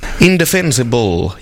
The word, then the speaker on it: Indefensible: Indefensible, jag hade svårt att uttala det här namnet, men det är väl som man säger det. – Ja, precis, bra. Jag säger det, du sa det rätt.